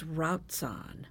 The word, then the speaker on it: Routzahn: Her married name is Routzahn, R-O-U-T-Z-A-H-N, or sometimes R-A-U-T-Z-A-H-N, which in German would be pronounced the same, Routzahn.